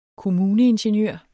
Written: kommuneingeniør

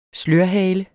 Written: slørhale

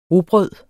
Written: rugbrød

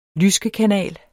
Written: lyskekanal

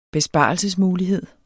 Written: besparelsesmulighed

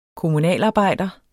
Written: kommunalarbejder

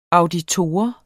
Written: auditor

